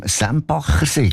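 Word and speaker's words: Sempachersee: Der Hans vom Sembachersee, gell? Das habe ich richtig gesagt.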